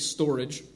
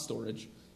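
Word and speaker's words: storge: It looks like storage, but it's not storage. It's storage.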